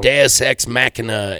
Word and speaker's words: Deus Ex Machina: Deus. You know. Deus Ex Machina is the proper way to say that.